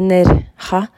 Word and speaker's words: Nerja: turen vi hadde i år til NERIA Eh, det uttales jo egentlig Nerja, men jeg orker ikke å si det nå, for jeg har så vondt i halsen.